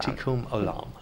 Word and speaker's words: Tikkun Olam: Tikkun Olam.